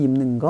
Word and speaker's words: 입는: 哎，这个句子里面有一个地方它叫 입는 거啊，这个입다加上了一个는啊，这个地方有音变，读的时候呢要读成 입는 啊 입는 입는 啊，所以키 큰 사람들이 입는 거 아니야?